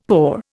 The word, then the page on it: paw: Indian